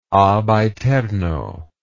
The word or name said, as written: ab aeterno